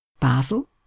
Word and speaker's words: Basel: And thirdly then, another example from Europe, the Swiss city, which has two pronunciations for its name, depending on whether you're thinking in French or in German, and that will be Bâle or Basel, described as follows.